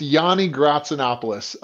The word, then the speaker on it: Yanni Gratsinopoulos: No, it is pronounced Yanni Gratsinopoulos. Which, when you have more vowels than consonants, is not a good thing. But yeah, it's Yanni.